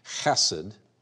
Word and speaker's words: chesed: It is chesed. The C is silent. And we will just speak it as it looks.